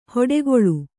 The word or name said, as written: ಹೊಡೆಗೊಳು